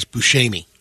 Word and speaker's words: Buscemi: The proper Italian pronunciation is Buscemi.